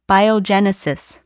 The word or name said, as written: biogenesis